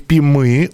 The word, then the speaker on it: пимы: В Томской области есть обувь Пимы. Ударение на «ы» — это валенки.